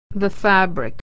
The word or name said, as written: fabric